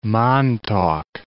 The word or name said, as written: Montauk Point